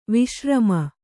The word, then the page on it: ವಿಶ್ರಾಮ: viśRāma